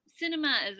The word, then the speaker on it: Sinema: And meanwhile, we have these at least two Democratic senators, Joe Manchin of West Virginia and Kristen Sinema of, is it Sinema? How do you pronounce it? – Sinema? Sinema, as far as I understand it.